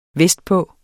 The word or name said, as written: vestpå